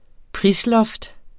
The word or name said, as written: prisloft